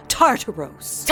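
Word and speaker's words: Tartarus: And by the way, it's pronounced Tartaros.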